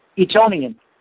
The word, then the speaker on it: Etonian: He explained to Christopher Hollis, a fellow Eton, how do you pronounce that? Etonian. – Etonian, thank you.